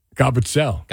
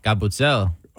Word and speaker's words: Gabutzel: So it happened at different restaurants, multiple courses of outstanding food that ends with, now, if I butcher the pronunciation, Capuzel? – Gabutzel. – Gabutzel.